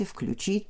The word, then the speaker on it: включить: включить, переключить, заключить и так далее. Здесь старшая норма, конечно, действует вот как раз с ударением на окончание включить включит.